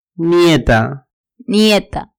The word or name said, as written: nieta